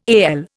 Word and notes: AL: Indian

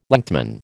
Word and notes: lengthman: American English

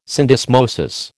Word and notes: syndesmosis: American English

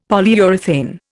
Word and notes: Polyurethane: Indian

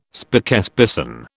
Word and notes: spokesperson: British English

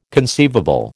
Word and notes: conceivable: American English

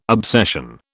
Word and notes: obsession: British English

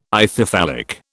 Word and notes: Ithyphallic: British English

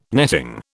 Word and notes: netting: British English

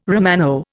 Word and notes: Romano: Indian

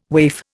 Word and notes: waif: Indian

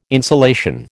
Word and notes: insolation: American English

